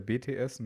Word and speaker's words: BTS: also oder die deutsche Aussprache ist ja BTS, ne?